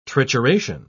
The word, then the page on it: trituration: British English